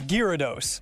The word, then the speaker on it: Gyarados: Did he say it right? – I was a Charizard guy. No, he did not. – What is it? – It's Gyarados.